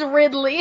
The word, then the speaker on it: Ridley: You keep saying Wrigley. It's Ridley.